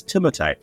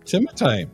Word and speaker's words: Timothée: Timothy Chalamet's correct pronunciation of his name is Timothée. There you go. – Oh, okay. Timothée.